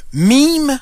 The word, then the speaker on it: meme: Bon, je vous explique le terme meme, M-E-M-E, ça s'écrit comme meme, mais c'est en anglais.